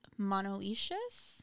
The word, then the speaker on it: monoecious: And these are called monoecious? – Monoecious, I believe is how it's pronounced.